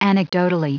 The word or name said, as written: anecdotally